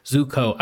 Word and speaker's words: Zuko: So yeah, you're introduced to the villain, which is Prince Zuko and Zoku? Zuko? How do you pronounce the last name? Zuko.